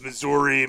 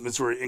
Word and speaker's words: Missouri: If I ever run for governor, there are two ways to pronounce Missouri, Missouri, and Missouri. What I took offense at was that you affiliated pronouncing it as Missouri with people lacking in intelligence or sophistication.